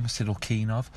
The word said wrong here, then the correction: Sidelnikov: Sidelnikov. Kirill Sidelnikov.